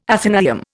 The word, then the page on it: athenaeum: Indian